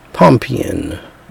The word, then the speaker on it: Pompeian: Say it again. Pompeian.